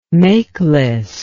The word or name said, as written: makeless